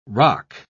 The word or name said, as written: roc